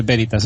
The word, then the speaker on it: Veritas: Y en Cafeína Veritas. – Cafeína Veritas. – En cafeína Veritas, perdón, que me afearon esto. El primer comentario que tuve fue muy, me gusta mucho, pero se dice Veritas, eso es drújula.